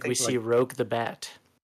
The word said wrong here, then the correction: Rouge: Is it Rouge? Rogue or Rouge? I was going to say, have we been saying it wrong at my house? – It's Rouge. – I'm pretty sure it's Rouge.